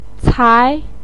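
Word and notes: cai2: tone 2

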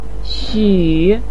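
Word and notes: xu: tone 3

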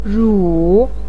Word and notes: ru: 3

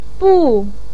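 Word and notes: bu4: tone 4

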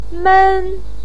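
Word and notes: men1: pinyin; tone 1

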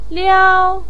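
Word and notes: liao1: pinyin; tone 1

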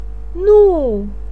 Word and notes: nu: 4

